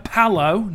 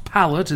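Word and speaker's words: Pallot: Narina Palow, not Pallet, as many DJs piss pronounced it.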